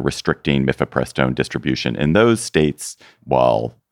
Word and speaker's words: mifepristone: And Kaczmarek, as hoped, delivered last week what they hoped for, what they anticipated, issuing an injunction against the medication abortion drug mifepristone. I pronounced it correctly, on the grounds that the FDA supposedly approved it hastily more than 20 years ago.